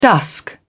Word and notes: dusk: British English

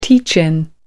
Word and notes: teach in: American English

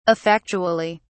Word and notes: effectually: British English

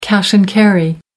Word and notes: cash-and-carry: American English

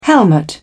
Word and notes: helmet: British English

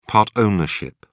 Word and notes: part ownership: British English

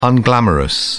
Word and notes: unglamorous: British English

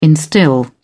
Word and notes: instil: British English

